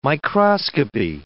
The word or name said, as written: microscopy